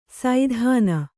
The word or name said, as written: ಸೈಧಾನ